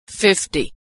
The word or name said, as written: 50